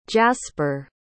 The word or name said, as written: jaspar